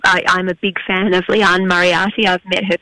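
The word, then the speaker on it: Liane: Leanne.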